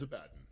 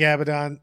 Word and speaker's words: Abaddon: It's hard. It is spelled Abaddon. It's just easier to say Abaddon.